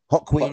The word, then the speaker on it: Joaquin: you said his name was like it sounded like you said Hot Queen Phoenix. – Joaquin Hot Queen. – Phoenix. Hock the Queen Phoenix. Joaquin. How do we say it? Joaquin.